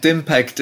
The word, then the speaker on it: DIMPACT: Is DIMPACT the way I should be pronouncing it? Is that okay? – I think DIMPACT is the pronunciation I use, yeah.